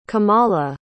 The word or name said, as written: komala